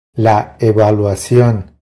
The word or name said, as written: La evaluacion